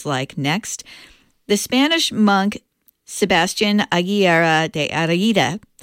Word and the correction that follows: Heredia: I pronounced that wrong, Heredia, worked as an organist at the cathedral in Huesca from 1585 to 1603.